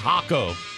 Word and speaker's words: Jaco: Speaking, you don't pronounce the J. I found that out when I was fishing in Costa Rica. Well, outside of Jaco, it's spelled J-A-C-O. So see, so this count as J-L-L-I-S-C-O.